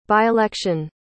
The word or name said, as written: by-election